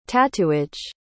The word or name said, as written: tattooage